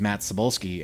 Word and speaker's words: Matt Cybulsky: People support Just because when I introduced myself, I said, my name is Matt Cybulsky.